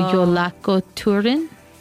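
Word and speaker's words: Jólakötturinn: I want to say that this one, the J is going to be pronounced like a Y. – Oh. – So Jólakötturin.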